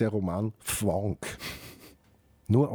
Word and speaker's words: Fvonk: Und die Sue Imhoff macht gemeinsam mit der Susanne Höllier eine Sendereihe, die heißt Fwonk. – Fwonk dich frei. – Und Fwonk schreibt man F-V-O-N-K.